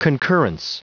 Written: concurrence

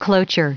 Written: cloture

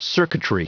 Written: circuitry

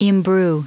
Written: imbrue

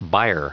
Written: byre

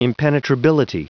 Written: impenetrability

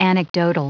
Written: anecdotal

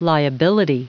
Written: liability

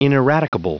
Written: ineradicable

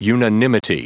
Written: unanimity

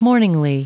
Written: mourningly

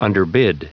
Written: underbid

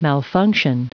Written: malfunction

